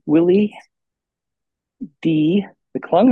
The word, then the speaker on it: Willie D McClung: We have Willie D McClung.